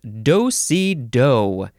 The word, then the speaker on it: do-si-do: The next word is do-si-do. And it looks like the last syllable is emphasized more than the other ones.